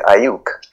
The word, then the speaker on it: Aiyuk: So my second receiver is Brandon Ayuk. – Can you spell that, please? – A-I-Y-U-K. – Oh, okay. Ayuk. – A-I-Y-U-K.